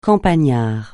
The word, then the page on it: campagnard: francais